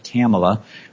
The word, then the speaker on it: Kamala: Kamala rhymes with Pamela.